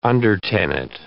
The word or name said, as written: undertenant